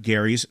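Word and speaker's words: Gerry's: But when the term gerrymander caught on, it wasn't with the hard G sound of Gary's surname.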